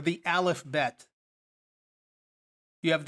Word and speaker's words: bet: If you go down to the next one, you have a bet, or sometimes pronounced with a vet, like Avenu, which would be our father, as a Av, but pronounced with a more of a V sound.